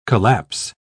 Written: collapse

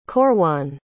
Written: corwan

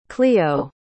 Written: clio

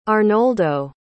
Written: Arnoldo

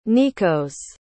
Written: nikos

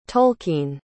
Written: tolkien

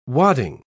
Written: wadding

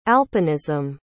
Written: alpinism

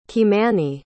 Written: kymani

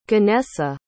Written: ganesa

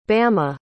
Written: bama

